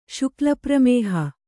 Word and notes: ಶುಕ್ಲಪ್ರಮೇಹ: Kannada

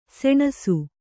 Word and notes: ಸಣ್ಣಿಸು: kannada

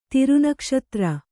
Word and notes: ತಿರುನಕ್ಷತ್ರ: kannada

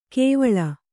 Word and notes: ಕೇವಳ: kēvaḷa

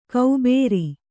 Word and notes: ಕೌಬೇರಿ: kaubēri

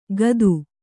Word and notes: ಕತ್ತು: kaattu

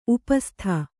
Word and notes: ಉಪಸ್ಥ: kannada

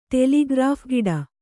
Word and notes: ಟೆಲಿಗ್ರಾಫ್‍ಗಿಡ: ṭeḷigraph giḍa

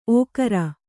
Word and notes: ಓಕರ: ōkara

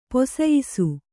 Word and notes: ಪೊಸಯಿಸು: posayisu

